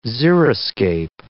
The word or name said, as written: xeriscape